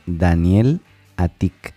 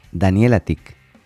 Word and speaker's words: Daniel Atik: Mi nombre se pronuncia Daniel Atik. Daniel Atik. Daniel Atik.